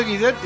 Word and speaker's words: Guidetti: Martín dice que si el apellido Guidetti se pronuncia en italiano es Guidetti.